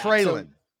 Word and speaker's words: Treylon: Treylon.